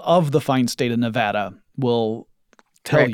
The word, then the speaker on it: Nevada: So I went to CES 2019 in Las Vegas, Nevada, which I have learned is pronounced Nevada. Not Nevada.